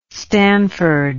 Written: stanford

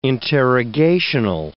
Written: interrogational

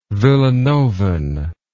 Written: villanovan

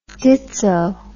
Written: ditso

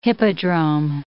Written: hippodrome

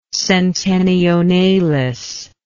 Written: centenionalis

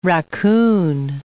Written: racoon

